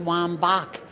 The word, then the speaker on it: Wambach: It is Wambach. Anybody that knows Johann Sebastian Bach, B-A-C-H, you would think that they would get Wambach as well, but obviously they don't.